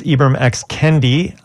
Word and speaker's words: Ibram X. Kendi: It's Ibram X. Kendi.